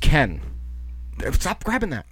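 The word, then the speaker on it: Cannes: It's actually pronounced Ken, the film festival. So it got great reviews at Ken, but a lot of critics... – Ken? – Were like Ken Boyle? – Yes, like Ken Boyle.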